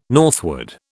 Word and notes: northward: British English

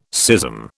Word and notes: schism: British English

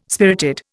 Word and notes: spirited: Indian

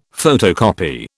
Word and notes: photocopy: British English

